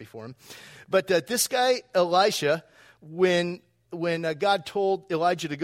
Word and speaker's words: Elisha: It's about Elisha. I'm pronouncing it E-L-I-S-H-A, not Elijah.